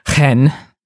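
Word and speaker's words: chen (חן): which if I'm pronouncing this correctly, is pronounced chen. Mm-hmm. It's like hen, like the chicken, but with something in your throat.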